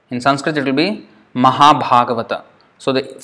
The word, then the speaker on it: Mahabhagavata: But in Bengali they would say Moha, because the first one A is a short A, so it's O. But the second one is A, so that is A. And Moha Bhagavat. This is how it would pronounce. Moha Bhagavat. But it's Mahabhagavata. So, even if you can't pronounce it exactly like a Bengali, it doesn't matter. If you say Mahabhagavat, it is still not wrong.